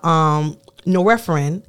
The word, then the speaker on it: norepinephrine: Then you have norepinephrine, if I'm pronouncing that correctly. Yep.